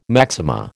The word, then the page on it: maxima: American English